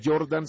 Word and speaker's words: Jordan: Bueno, marcando mi nombre, Vanny Jordan, ¿cómo se escribe? – Se escribe B pequeña, o sea, B de vaca, A-N-N-Y, y después espacio Jordan, J-O-R-D-A-N, Vanny Jordan.